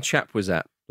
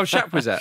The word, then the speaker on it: Chapuisat: Stefan Chapuisat. – Love that. – Ooh, what a pronunciation of Chapuisat. – Oh, Chapuisat. – Chapuisat.